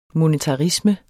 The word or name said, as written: monetarisme